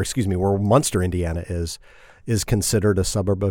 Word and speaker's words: Munster: In a town called Munster, not like the cheese.